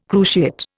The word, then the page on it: cruciate: Indian